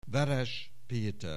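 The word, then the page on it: Veres Peter: Hungarian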